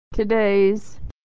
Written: today's

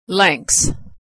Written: lengths

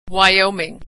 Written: Wyoming

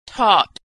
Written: taught

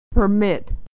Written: to permit

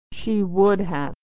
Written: she would have

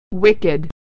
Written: wicked

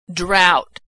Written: drought

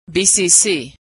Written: BCC

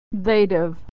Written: they'd've

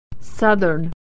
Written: southern

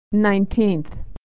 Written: nineteenth

